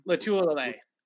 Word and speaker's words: Lotulelei: Yeah, I mean, Star how do you pronounce his name? – Lotulelei. – There you go.